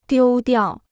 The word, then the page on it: 丢掉: diū diào